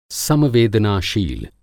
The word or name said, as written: सम-वेदनाशील